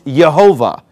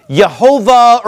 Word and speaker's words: Jehovah: And if you pronounce it the way it's written on the page right now, it's Jehovah. So that's where we get in English, I-E-H-O-V-A-H in our 1611 King James Bible.